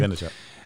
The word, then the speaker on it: Vaynerchuk: Vaynerchuk.